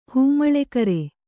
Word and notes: ಹಿಮ್ಮೇಳಕಾರ: himmēḷakāra

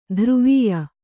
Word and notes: ಧ್ರುವೀಯ: dhruvīya

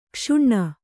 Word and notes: ಕ್ಷುಣ್ಣ: kṣuṇṇa

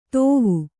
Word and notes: ತೋವು: kannada

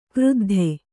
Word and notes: ಕ್ರುದ್ಧ: kruddha

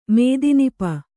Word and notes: ಮೇದಿನಿಪ: Kannada

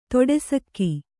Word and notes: ತೊಡೆಸಕ್ಕೆ: toḍe sakke

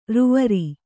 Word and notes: ರೂವರಿ: rūvari

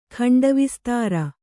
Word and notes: ಖಂಡವಿಸ್ತಾರ: khaṇḍa vistāra

